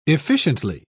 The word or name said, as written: efficiently